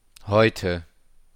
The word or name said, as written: heute